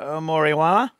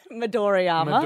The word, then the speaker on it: Midoriyama: Moriwa. – Midoriyama.